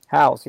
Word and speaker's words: Howes: Ed Milet was on Lewis, I don't know how to say his last name, Howes or Hoes. – Howes, yeah.